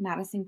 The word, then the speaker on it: Madisun: It's just my name, Madison Gray, spelled with a U like the sun.